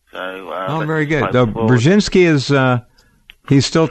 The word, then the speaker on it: Brzezinski: Brzezinski.